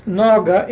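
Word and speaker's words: Naga (נגע): Well, my Gesenius Hebrew Chaldee lexicon of the Old Testament, pages 531 and 532, word number 5060, pronounced Naga, N-A-G-A, for the word, quote, touch, unquote, besides meaning, quote, to touch or reach into, unquote, means, quote, to violate to injure, unquote.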